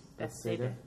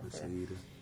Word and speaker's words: Bethsaida: and it's healing of a paralytic at the pool of how do you say that? – Bethsaida. – Yeah, Bethsaida. And it basically says that Jesus was there,